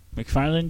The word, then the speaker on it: McFarland: So, in other news, Disney released a movie recently called McFarlane USA. – McFarland, USA. – Oh, they renamed it? – Yeah. – I'm pronouncing that wrong? – Yes.